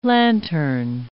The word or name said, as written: lanthorn